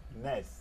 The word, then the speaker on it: NES: They told you how to pronounce it, and it's NES. – NES. – NES. Like Howard and NES.